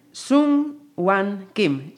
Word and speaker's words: Sung Whan Kim: Me acompaña, voy a ver si lo digo bien, Sung Wan Kim.